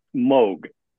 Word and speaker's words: Moog: Do you say Moog or do you say Moog? – Well, and actually, I figured we'd cover that too. Yes, it is Moog. Long Moog.